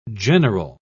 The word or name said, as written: general